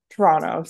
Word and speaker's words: Toronto: Toronto, sorry, you don't pronounce the T. – Oh. – You don't pronounce the second T.